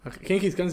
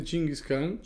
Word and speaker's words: Chinggis Khan: Genghis Khan se dice Chinggis Khan.